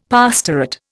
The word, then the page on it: pastorate: Indian